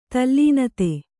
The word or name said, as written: ತಲ್ಲೀನತೆ